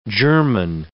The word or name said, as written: germen